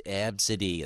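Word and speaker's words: Abcde: And she goes, "No, it's pronounced absidy."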